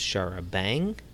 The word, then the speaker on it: charabanc: That vehicle usage does suggest a possible link with a name for a form of UK sightseeing bus that is charabanc. Pronounced charabang, derives from the French charabanc, carriages with benches.